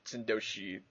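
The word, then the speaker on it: Synecdoche: Or Sindoshi. It's not Schenectady. It's like Sindoshi.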